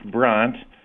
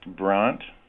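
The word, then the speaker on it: Brandt: Okay, well, it's actually pronounced Brant.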